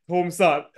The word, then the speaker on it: Tom-san: He's not even saying Tom the way he would say Tom in English, man. He's saying Tom son.